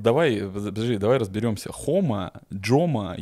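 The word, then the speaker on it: Joma: В Испании преимущественно все говорят Хома.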